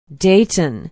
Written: Dayton

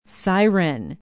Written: siren

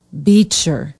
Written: beecher

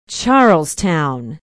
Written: charlestown

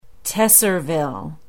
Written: tesserville

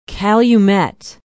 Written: calumet

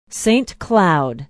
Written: Saint Cloud

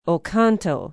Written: oconto

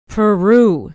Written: peru

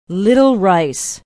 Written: little rice

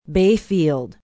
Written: bayfield

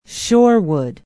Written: shorewood

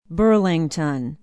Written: burlington

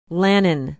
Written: lannon

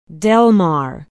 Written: delmar

